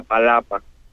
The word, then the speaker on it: Palapa: It's called La Palapa. – Okay, you're going to have to spell that for us. Go ahead. – P-A-L-A-P-A.